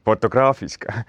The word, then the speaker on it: Fotografiska: Võibolla ma mainin enne ühe asja, kui me siin fotografiska või kuidas seda hääldama peab... – Fotograafiska.